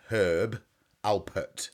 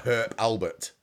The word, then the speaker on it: Herb Alpert: Herb Alpert. – Yeah, he's not an Albert. – It's not Herb Albert.